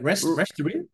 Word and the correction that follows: restaurateur: Restaurateur. I was 20-something years, but not anymore.